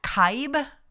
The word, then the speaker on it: Kibbe: Z-U-B-Y, Zuby. Well, okay. He did an interview the other day with Matt Kibbe, I believe is how you say his last name, K-I-B-B-E.